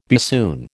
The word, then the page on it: bassoon: American English